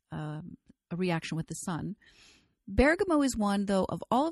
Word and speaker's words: bergamot: And the one that I chose particularly from the ester family is bergamot, which many people pronounce bergamot. I say bergamot because that's the way I learned to pronounce it when I was in Europe.